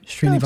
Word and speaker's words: Srinivas: Indian mandolinist Yu Srinivas. I'm not saying that right, am I? – Srinivas. No, close enough.